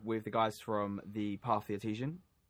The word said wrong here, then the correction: artisan: Artesian, is that how you say it? – Artisan. – Artisan? Right.